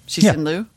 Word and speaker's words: Liu Cixin: But Liu Cixin, who wrote the three body problem is also Shishin Lu. – Shishin Lu, Lu Shishin. You can depend. You can claim to be saying it right either way.